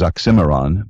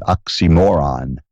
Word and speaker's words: oxymoron: The word is oxymoron, but he, for comedic effect, pronounced it oxymoron, stressing moron.